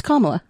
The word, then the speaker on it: Kamala: It's Kamala.